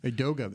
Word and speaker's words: Edoga: I hope I got his name right. Chuma Edoga. – Edoga, that's right.